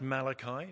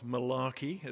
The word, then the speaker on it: Malachi: It's pronounced Malachi, not Malarkey, as somebody once pronounced him.